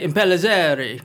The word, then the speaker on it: Impellizzeri: Well, the trick is to pronounce it with an Italian accent, and you can never get it wrong. – Okay. – So you can say Impellizzeri or Zari or, you know, as long as you sound a bit Italian, although she's Latina.